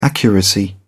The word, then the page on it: accuracy: British English